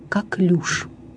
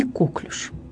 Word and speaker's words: коклюш: Поэтому в современном русском литературном языке единственно правильной признается форма «коклюш», не «коклюш». Запомните, пожалуйста, «коклюш» – название инфекционного заболевания. Ударение падает на последний слог, то есть на гласный «ю». При изменении слова ударение не смещается.